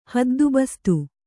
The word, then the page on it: ಹದ್ದುಬಸ್ತು: haddu bastu